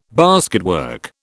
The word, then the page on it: basketwork: British English